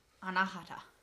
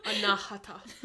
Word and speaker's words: Anahata: Did we look up how to pronounce it? – Anahata? – Anahata? Anahata. I think it's, like, Anahata. – Anahata. – Yeah, I think the emphasis is, like, okay. On the ha.